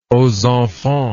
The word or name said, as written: ozenfant